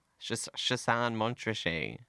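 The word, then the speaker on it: Chassagne-Montrachet: Do you know how to say it better? – No, that sounds good. – What was it? – Chassin Montrachet.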